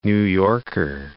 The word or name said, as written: New Yorker